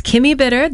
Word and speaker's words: Bitter: The name is Kimmy Bitter. That is K-I-M-M-I and bitter like the taste, B-I-T-T-E-R.